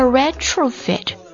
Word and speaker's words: retrofit: A retrofit may involve putting in new door jams. 注意一下这个 jamb, 它这个 b 是不发音的，它是表示这个窗子啊，或者说这个门的侧柱。 Jams 有点像果酱那个单词啊，读音是一模一样的。 A retrofit may involve putting in new door jams.